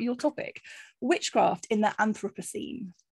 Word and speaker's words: Anthropocene: What an Anthropocene, is that how you say it? – That is how you say it.